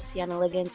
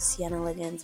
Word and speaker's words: Siena Liggins: My name is Sienna Liggins. You can follow me everywhere at Sienna Liggins. That's spelled S-I-E-N-A-L-I-G-G-I-N-S.